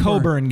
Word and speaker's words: Cockburn: C-O-C-K-B-U-R-N. That's Cockburn. It's Coburn, guys. Come on.